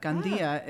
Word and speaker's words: Gandia: Actually, that is correct, but I don't really use the accent inflection. I sort of use the Americanized version. – Gandia. – Of our name, but Gandia is the correct pronunciation.